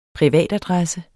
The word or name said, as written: privatadresse